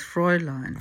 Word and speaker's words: Fräulein: Actually, the Germanic accent is on the first syllable, so it's Fräulein.